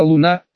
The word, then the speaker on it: Луна: «Я Луна», сказала она, делая ударение на первый слог.